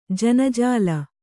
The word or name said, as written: ಜನಜಾಲ